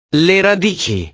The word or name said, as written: le radici